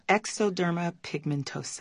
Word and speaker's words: xeroderma pigmentosum: xeroderma pigmentosum. Is that right? – Exoderma pigmentosum.